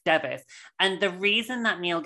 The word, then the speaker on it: Device: That surname is pronounced Devis.